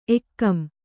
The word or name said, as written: ಏಗಂ